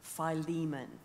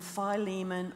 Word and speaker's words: Philemon: He's writing to a friend called Philemon. Now, you can call him Philemon or Philemon, as rhyming with Pokemon. Philemon, okay? Doesn't really matter. Depends whether you're American or Australian.